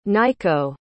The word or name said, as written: Nykko